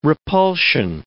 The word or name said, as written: repulsion